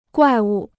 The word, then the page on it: 怪物: guài wu